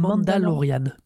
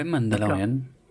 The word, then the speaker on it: Mandalorian: C'est comme ça ça se prononce c'est, The, Mandalorian. Ouais, Mandalorian.